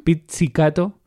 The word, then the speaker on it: pizzicato: pizzicato, como pizza,